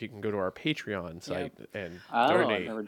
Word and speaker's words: Patreon: Is that how you pronounce those? Like the podcast that people charge for? – Oh, Patreon. – Patreon. – I've only seen it written. I've never actually heard it said out loud, so I wasn't sure how to pronounce it.